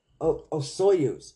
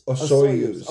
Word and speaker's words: Osoyoos: It says Oyosoos, right? – It says here Osoyoos. – Osoyoos.